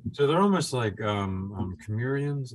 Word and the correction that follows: chimeras: Is that how it's pronounced? – Chimeras. – Yeah. – No, these things are chimeras.